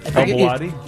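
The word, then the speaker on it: Al Bawadi: El Bawadi? – Yes.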